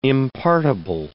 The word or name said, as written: impartible